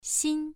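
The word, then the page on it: xin: tone 1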